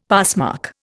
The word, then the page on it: passmark: Indian